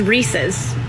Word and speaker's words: Reese's: A pet peeve that I have that I'll never get over is people pronouncing it Reese's. It is Reese's. It is a guy named Reese, and it is his. It's his. Reese's. And it's in the commercial. Reese's Puffs, Reese's Puffs. R-E-E-S-E-S. But it's Reese's. The company is not mispronouncing their own name.